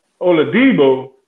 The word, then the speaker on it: Oladipo: Victor Oladipo? – Oladipo.